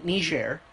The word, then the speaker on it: Niger: Like, I mean, Niger. – What? Oh, that's how you pronounce it? – Yes, there's only one G. – Ooh, and it's French.